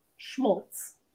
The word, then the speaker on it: schmaltz: As a perfect medley of adult contemporary schmazelt. Okay, hold on. S-H-M-A-L-T-Z. Schmaltz? How do you say that? – I'm sorry, I don't know. Schmazelt? What is that? – Schmaltz. – That was a schmaltz.